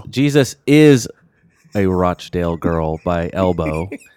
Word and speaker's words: Rochdale: idyllic town of Rochdale. – Rochdale.